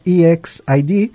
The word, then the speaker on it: EXID: Estuvimos escuchando a la agrupación surcoreana EXID, o correctamente pronunciado EXID, interpretar su corte musical Are You Hungry, que salió en el año 2016.